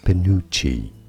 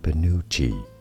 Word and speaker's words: penuche: Penuche. Oh. Stress on the second syllable. Both British and American pronunciations are similar here. As Penuche.